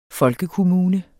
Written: folkekommune

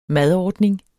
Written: madordning

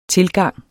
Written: tilgang

